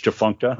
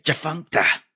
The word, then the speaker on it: Tchefuncta: And I guess I'm saying it right because you spell it T-C-H-E-F-U-N-C-T-A. That's Chifuncta, huh? – Don't fake the Chifunct on the nasty dunk.